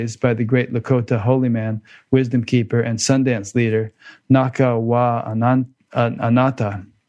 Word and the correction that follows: Nacha Wa Anata: Did I pronounce that right? – It's Nacha Wa Anata.